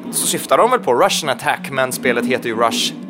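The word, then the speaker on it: Rush'n Attack: Så Rush and Attack heter det ju. Men Russian Attack uttalas det, och det låter ju jävligt likt Russian, som är ryska liksom.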